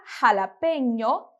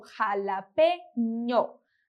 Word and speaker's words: jalapeño: A palavra jalapeño, que significa pimenta jalapeño, a gente vai pronunciar como jalapeño. Jalapeño.